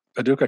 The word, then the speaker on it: Paducah: I know you were born in Charlottesville, Virginia, and you grew up in Paducah, Kentucky.